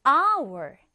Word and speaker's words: hour: 자, 얘는 our. 생긴 것은 스펠링으로 따지면 첫 글자가 자음이에요. 그런데 발음할 때는 얘 어떻게 발음했죠? our. 발음을 해보면 첫 글자가 모음 소리가 나게 됩니다.